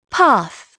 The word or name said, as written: path